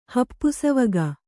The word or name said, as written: ಹಪ್ಪುಸಾವಿಗೆ